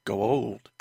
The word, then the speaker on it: Goa'uld: Goa'uld.